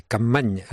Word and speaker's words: Capmany: Capmany. N-Y se pronuncia ñ en catalán.